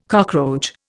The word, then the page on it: cockroach: Indian